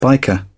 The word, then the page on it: biker: British English